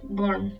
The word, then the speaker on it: Bourne: Pienso en Bourne, que... Bourne, como nunca no lo sé pronunciar, la de Matt Damon. – Sí, Bourne.